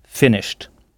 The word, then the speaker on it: finished: Donc, on prononce le « ed » « t » tout simplement parce qu'il serait difficile de prononcer « finished ». Donc, c'est bien « finished ».